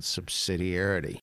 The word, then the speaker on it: subsidiarity: because I want to talk about something called subsidiarity. – It's like a six-syllable word.